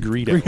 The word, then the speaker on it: Greedo: Yeah, Greedo. It's pronounced Greedo.